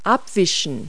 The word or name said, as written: wipe